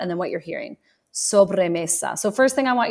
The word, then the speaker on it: sobremesa: S-O-B. R-E-M-E-S-A. So again, now I'm going to say it, sobremesa, sobremesa.